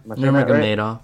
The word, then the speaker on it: Nurmagomedov: Dude, first off, Khabib, I'm going to try and say his name, Nurmagomedov? – Yeah, Nurmagomedov. – Okay, close enough.